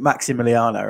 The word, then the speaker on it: Massimiliano: So how you how you spell it is Massimiliano. So it's double S, but you pronounce it Maximiliano, right?